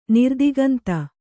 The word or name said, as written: ನಿರ್ದಿಗಂತ